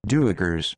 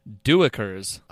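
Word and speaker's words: duiker: Duikers. – Duikers.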